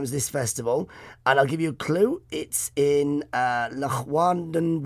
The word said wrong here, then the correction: Llanwrtyd: Wales, in Wales. I think that's how you say it. It's Llanwrtyd, Wales, in Wales.